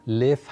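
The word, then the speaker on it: لف (Лев): زندگیش خب ایونزاده بود کنت بود تولستوی لف هست تلفظ اسمش در روسی ما از فرانسه چون اولین ترجمه های تولستوی رو خوندیم فرانسوی ها لف به معنی شیر رو کردن لئو لئون شیر و اینطوری لئون تولستوی در ایران جا افتاد در حالی که ظاهرا لف تالستوی بله.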